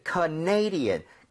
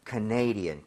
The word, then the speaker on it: Canadian: 왜냐면 캐나다는 Canada 이렇게 가는데 네. – 이게 캐나다 사람 이러면은 Canadian 강세가 뒤로 하나 밀려요. – 아. – 그래서 살짝 헷갈릴 수 있습니다. – Yes. – So Canadian, Canadian.